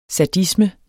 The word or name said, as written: sadisme